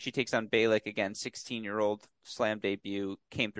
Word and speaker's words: Bejlek: B-E-J-L-E-K. – I think it's Bjelić.